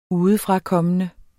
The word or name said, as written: udefrakommende